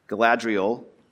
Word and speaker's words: Galadriel: And after they've talked about it and they're getting ready to go to bed, Galadriel, did I say it right, David? Nailed it.